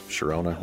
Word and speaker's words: Sharona: The Knack's My Sharona also gained, since Sharona rhymes with Corona.